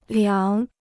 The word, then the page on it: 凉: liáng